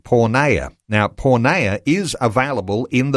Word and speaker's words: Porneia: It's called Porneia. And it's spelt P-O-R-N-E-I-A. E-I-A.